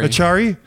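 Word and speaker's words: Acciari: I need to play Asiari. Is that how you pronounce it? – Achari.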